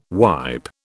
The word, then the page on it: wipe: British English